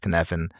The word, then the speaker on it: Cynefin: It's pronounced Kinefin, but it's spelled Cynefin. And it goes through four stages: chaotic, complex, complicated, clear.